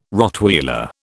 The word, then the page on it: Rottweiler: British English